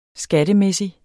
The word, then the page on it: skattemæssig: -ˌmεsi; -ˌmεˀsi